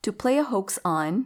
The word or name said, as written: to play a hoax on